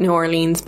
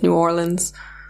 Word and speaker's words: New Orleans: Yeah, over here we call it New Orleans, but I think it is New Orleans.